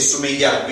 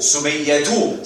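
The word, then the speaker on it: سمية: We just say Sumayya. We don't pronounce that ta, we just pronounce it like a ha, Sumayya.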